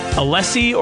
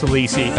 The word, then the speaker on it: Alesi: I'm going to say Alesi because it's, of Sandy Alesi casting. – I think it's Alesi. – I think it is, too. It's spelled Alesi, but I think it's pronounced Alesi.